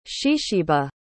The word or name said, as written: Shesheba